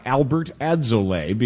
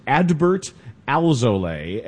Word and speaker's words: Adbert Alzolay: And get used to the pronunciation of that name. It's not Albert Adzolay, because it's very easy for your brain to transpose to something that much more familiar. It is Adbert Alzolay, and he's putting together a season at AAA for the Cubs.